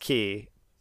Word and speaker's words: chi: But it's pronounced key. So let me type it out. So it's X, open bracket, C-H-I, close bracket, pronounced key.